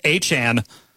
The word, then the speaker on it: Etienne: it's pronounced Achan. Like Devon Achan.